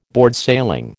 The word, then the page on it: boardsailing: American English